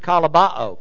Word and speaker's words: kolobo: That's the word kalabao, K-O-L-O-B-O, long O.